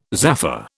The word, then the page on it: zaffre: British English